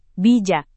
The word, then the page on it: bijak: Indonesian